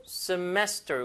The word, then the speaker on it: semester: New semester. 이거 중간에 강세입니다.